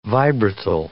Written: vibratile

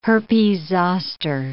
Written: herpes zoster

